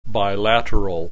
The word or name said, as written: bilateral